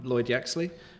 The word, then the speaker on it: Yaxley: Yeah, I'm Lloyd Yaxley.